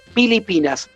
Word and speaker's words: Pilipinas: Filipinas tiene, tiene dos idiomas oficiales, eh, uno es el filipino, pero que no se escribe así, sino que se escribe con P. El nombre dicho Filipinas, dicho en filipino, se dice Pilipinas.